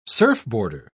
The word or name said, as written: surfboarder